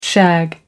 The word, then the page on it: shag: American English